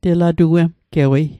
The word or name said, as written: telatuemgewei